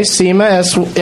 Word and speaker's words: Seyma: So Nick is playing for Jordan, and Lennon is playing for, is it pronounced Seema? Okay, Seema, S-E-Y-M-A.